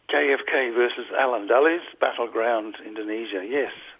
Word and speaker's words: Dulles: D-U-L-L-E-S. – Yeah, but the pronunciation, I was corrected in the way I was saying it. It comes from the word Douglas, apparently. – All right. – The great-great-grandfather of Alan was working in the East Indies years ago, mm-hmm. Indonesia, that is, mm-hmm. when it was Dutch, and the Dutch G, the glottal G, when they pronounced Douglas, it came out Dallas, without the G got swallowed down the throat. And Douglas became Dulles.